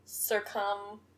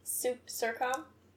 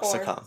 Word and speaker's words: succumb: Kind of hinting at, like, you know, she's kind of giving up and just going to succumb to death. Is that how you say it? Succumb? – No, succumb. – Succumb. – Succumb. – Succumb. – Yeah, succumb.